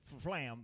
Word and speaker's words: Fflam: This name is spelled F-F-L-E-W-D-D-U-R. – Nicholas Flem? – Fluter. Oh, and his last name is Flam, which is F-F-L-A-M.